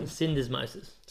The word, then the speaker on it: syndesmosis: And look, for the Reds, Caleb Timu came off the bench back from some Cindy. – From some Cindy? – How do you say? – How do you say it? – Sprained ankle, basically. – We'll no, it's not a sprain. It's a high ankle sprain. – High ankle sprain. – Syndesmosis. – Syndesmosis? – Yeah.